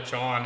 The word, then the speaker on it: jawn: Oh, I know what John. I know what John is. I know what John is. – Are Tony and I saying it right? John. – John. J-A-W-N. John.